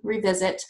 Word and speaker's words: Revizzit: It's spelled R-E-V-I-Z-Z-I-T, Revisit. And those can stay evergreen.